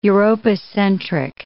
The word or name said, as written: Europocentric